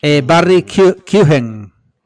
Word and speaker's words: Barry Keoghan: Barry Keoghan. Se pronuncia así porque el otro día estuve con un amigo irlandés y le dije, ¿cómo se pronuncia? Y él lo pronunció y digo, repítelo, que yo lo voy a repetir fonéticamente hasta que me lo aprenda.